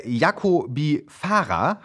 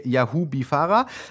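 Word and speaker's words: Yaghoobifarah: Dann haben wir Hengame Jacobi-Farah. Habe ich das richtig ausgesprochen? – Nicht falsch genug. – Naja, sie ärgert sich, sie ärgert sich sehr, wenn man ihren Namen falsch ausspricht. – Dann sage ich Hengame Yahubi-Farah.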